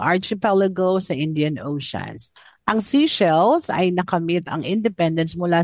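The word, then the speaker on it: Seychelles: Ang pronunciation pala po nito, seashells. Oh, seashells.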